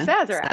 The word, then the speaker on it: Sazerac: Sazerac.